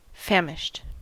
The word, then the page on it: famished: American English